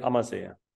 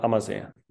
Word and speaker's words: Ammersee: Take a look at Lake Amazea. It's called Amazea.